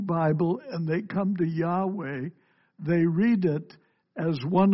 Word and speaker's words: Yahweh: That means Jehovah, or the Hebrew pronunciation is Yahweh.